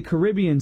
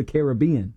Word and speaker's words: Caribbean: But I say Caribbean Sea, but I say Pirates of the Caribbean.